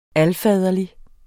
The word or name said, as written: alfaderlig